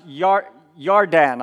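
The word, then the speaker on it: Yardan (ירדן): By the way, Jordan, it's Hebrew. It means, it's pronounced Yardan, I believe, with a Y.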